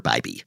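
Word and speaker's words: Bibee: So thanks to Reggie and correcting a pronunciation of mine from our last episode, I referred to Guardian starter Tanner Bibby. It's Tanner Bibby.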